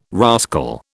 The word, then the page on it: rascal: British English